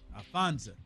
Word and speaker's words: Alphonza: Yeah, A-L-P-H-O-N-Z-A. – Alfonso.